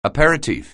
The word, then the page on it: aperitif: anglais